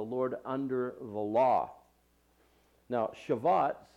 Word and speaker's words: Shavuot: It's also referred to as the Feast of Weeks, and it's pronounced in the Hebrew as the Feast of Shavuot.